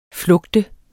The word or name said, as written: flugte